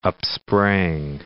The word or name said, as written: upsprang